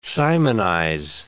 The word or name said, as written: simonize